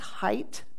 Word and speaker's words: Haidt: In particular, I've been watching Jonathan Haidt, pronounced Haidt, but spelled H-A-I-D-T, Jonathan Haidt.